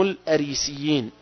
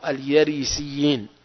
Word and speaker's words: الأريسيين: الأريسيين أو اليريسيين، تلفظ بالياء بالهمزة، الأريسيين أو اليريسيين.